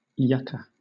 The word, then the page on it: yucca: British English